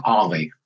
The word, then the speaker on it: Alli: Last name is A-L-L-I. Ali would be just one L, Ali. So it's Ali.